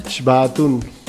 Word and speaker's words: Xbaatun: Com es pronuncia allò que s'escriu X-B-A-A-T-U-N? Bon dia, bona hora. – Bon dia. – Es pronuncia? – Xbatun.